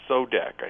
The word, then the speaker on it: SODAC: There's a division of IGT, I pronounce SODAC, I think, S-O-D-A-C. – That's correct.